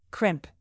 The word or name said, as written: crimp